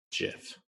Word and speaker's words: GIF: It is a soft G pronounced GIF.